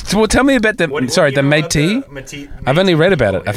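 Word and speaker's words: Métis: Well, I love the Matisse thing. Am I saying that right? – What are you talking about? – Metis? M-E-T-I-S.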